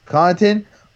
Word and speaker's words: Connaughton: Connaughton, 4-1 at +400, and then Dwight Howard at 5-1.